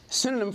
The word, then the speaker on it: synonym: Sometimes I say synonym. That's the word, not cinnamon. Synonym for the word obedience.